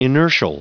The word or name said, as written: inertial